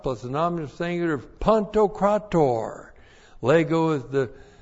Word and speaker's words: Pantokrator: Kurios is the Lord, and now we have a real special word, pantokrator, to describe the Lord. Pantokrator. P-A-N-T-O-K-R-A-T, long O-R. Pantokrator.